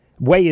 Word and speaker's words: Hue: Way. Way is spelled H-U-E if you've never heard of it.